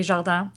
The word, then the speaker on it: Jardin: And for you folks that don't understand how she pronounced her last name, is Valerie, J-A-R-D-I-N. If you don't have that cool French accent.